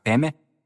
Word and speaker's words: падме: «Падме», что на тибетском произносится как «пеме», означает «в лотосе».